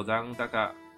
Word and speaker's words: 残高: チャージの残高が足りなくて支払えないといったことがなくなります。这边解释一下，那个連携汉字写作联系，读作連携，那就是合作。那接下来 type type 就是 type 形式啊，接下来一个汉字写作残和高，念作残高，残高就是余额。